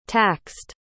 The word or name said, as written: taxed